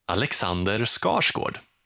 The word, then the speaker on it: Skarsgård: Alexander Skarsgård.